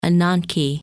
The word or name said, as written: Ananke